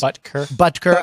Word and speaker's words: Butker: Harrison Buckner, whoever's name I mispronounced. – Butker. – I mispronounce his name.